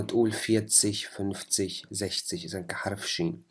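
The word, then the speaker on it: sechzig: ونفس الشيء هون كمان الزكس، قلنا هون تلفظ كحرف إكس التسيها، ولما بتصير 60 كمان مثل 16 هنيك قلنا زش تسين، صارت حرف شين. هون نفس الشيء كمان بتصير حرف شين، زش تسيش.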